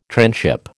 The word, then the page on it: tranship: American English